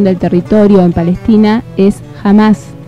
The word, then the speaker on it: Hamás: Se pronuncia Hamás y es una organización islamista palestina cuyo objetivo es la instauración de un Estado Islámico en la región histórica de Palestina.